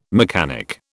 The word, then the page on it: mechanic: British English